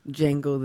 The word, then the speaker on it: Django: Django the D is silent.